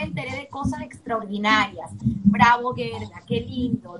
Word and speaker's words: Gerda: Me permito decir una anécdota porque me cuesta decir Gerda, uno tiende a leer Gerda, pero como bien me lo recordó nuestra recuerda la palabra gorda ponle la E y así pronunciarás Gerda perfecto.